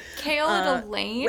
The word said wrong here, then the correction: Aelin: Aelin. – Oh my god. Okay. – Sorry, okay. – Emphasis on the wrong syllable.